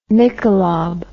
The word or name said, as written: Michelob